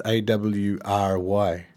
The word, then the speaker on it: awry: I've I've always struggled to pronounce A W R Y. – Awry. Awry.